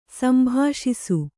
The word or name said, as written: ಸಂಭಾಷಿಸು